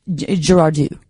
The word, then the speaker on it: Girardeau: and it is Cape Girardeau, just FYI. – What did I say? – Girardeau, like Depardieu.